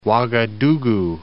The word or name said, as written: Ouagadougou